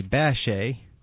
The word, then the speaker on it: Bechet: That's right, with Sidney Bechet, or as he pronounced it, Sidney Bechet, on the soprano, and I believe Thomas Morris on cornet, and Clarence Williams on the piano, of course.